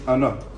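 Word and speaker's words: Ano (אנא): Kesem Ram Tzov writes, the real way to pronounce it is Ano, right? Milra, not Ano Hashem. Ano Hashem, Milra.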